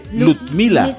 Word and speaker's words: Ludmila: No, Lud, con D. Ludmila.